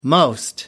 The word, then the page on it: most: American English